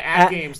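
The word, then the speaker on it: AtGames: It's actually pronounced AT Games because the console has 80 games on it.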